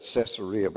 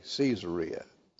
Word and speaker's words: Caesarea: I used to pronounce that Caesarea, but I realized it was named after Caesar, so it's got to be Caesarea.